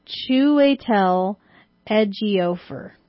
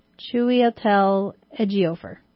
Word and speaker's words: Chiwetel Ejiofor: All right, so it's pronounced Chiwetel Ejiofor. – Three times fast? – Chiwetel Ejiofor.